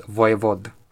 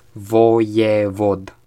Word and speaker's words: Voievod: But yes, it's pronounced, if you want to be accurate, it's pronounced Voivod. And yeah, it's just a little ego stroke, I suppose. – Yeah, Voivod. Voivod. Voivod. – Okay, how to explain this? Latin flat vowels. Voievod.